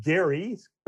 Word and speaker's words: Gerry: It comes from a governor back in the good old days, Elbridge Gerry, pronounced with a hard G, who led one of these efforts.